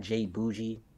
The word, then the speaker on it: J Boujii: I don't dance on TikTok um, at J Boujii, the letter J B O U J I I.